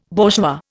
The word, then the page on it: bourgeois: Indian